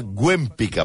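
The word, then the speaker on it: Łempicka: també coneguda com Tamara de Güempica, perquè, a veure, la L de l'Empica sí. es pronuncia Güempica. – Ué. Té aquesta Ué. ratlleta com el Boitila, com el papa.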